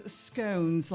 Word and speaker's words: scone: So apparently Ida says, I pronounce scones like stones. I pronounce scone like gone.